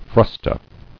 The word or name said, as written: Frusta